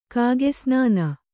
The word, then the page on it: ಕಾಗೆಸ್ನಾನ: kāge snāna